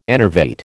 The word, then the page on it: enervate: American English